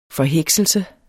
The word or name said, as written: forhekselse